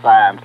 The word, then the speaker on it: Sines: It's pronounced Sines, like a stop sign.